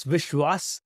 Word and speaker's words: Vishwas: Not exactly. It's Vishwas and Manral.